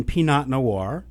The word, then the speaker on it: Pinot Noir: So the next faux pas is pronouncing the T in Pinot Noir or the T in Merlot.